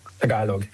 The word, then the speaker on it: Tagalog: Tagalog, yes.